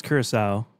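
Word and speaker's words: Curaçao: Did I say that right? – Yeah. – Curacao? Curacao? – I think it's Curacao, just because I remember having it on like a Cool Stuff on the Planet episode a million years ago.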